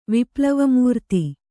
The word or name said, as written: ವಿಪ್ಲವಮೂರ್ತಿ